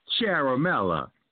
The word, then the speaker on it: Ciaramella: Yeah, but you supposedly pronounce his name Charamella. Now, I'm pretty good with the language. Ain't no way in hell you could take C-I-A-R-A, whatever, and make that C-H sound. That don't work.